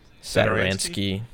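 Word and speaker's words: Satoranský: Satoransky. Is that how you say it? – Satoransky.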